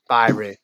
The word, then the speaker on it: Byrie: I think you said like Byrie, Byrie like Kyrie. Shit, Byrie.